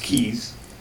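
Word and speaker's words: CIES: the only one that has been proposed, pronounced Keys, it's not too hard.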